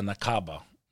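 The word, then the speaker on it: Nakba: And they call it the Nakaba, is how they say it,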